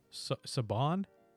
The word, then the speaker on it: Siobhan: Or, so the Irish name spelled S-I-O-B-H-A-N or whatever. – Whoa, what? – Yeah, it's spelled that way, but I think it's pronounced Saban? – Oh, Siobhan?